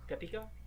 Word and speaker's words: Papika: Papika, Papika, is how you say it? Papika. – Uh, like it's like paprika, but without the R.